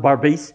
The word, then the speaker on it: Berbice: They were back on a river called the Barbice. Berbice it's spelled, but it's pronounced Barbice, which is now on the western side of Guyana.